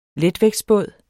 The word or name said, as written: letvægtsbåd